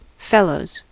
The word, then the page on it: ребёнок: [rʲɪˈbʲɵnək]; /rʲɪ.ˈbʲɵ̞.nək/